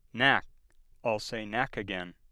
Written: knack

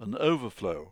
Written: an overflow

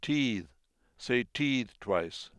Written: teethe

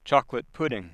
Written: choc

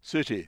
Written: city

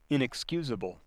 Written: inexcusable

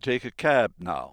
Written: cab

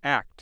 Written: act

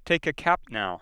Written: cap

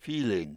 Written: feeling

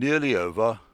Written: nearly

